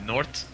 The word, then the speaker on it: G'Nort: Y para las personas que quieran ver una imagen de North, se escribe G'NORT. Y la G, sí, la G es silenciosa.